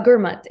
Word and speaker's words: Gurmat: What's the Jakara movement and what is Gurmat? Is that how you say it? – Yeah. So Gurmat. Yeah.